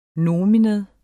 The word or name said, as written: nomen